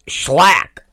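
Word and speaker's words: Shlak: So that's why the whole Schlack thing, I was calling him Shylock. And then he listened to the show, and he was like, it's Schlack. And that's how that relationship started, because he was correcting me on how to pronounce his name, but I had talked about him.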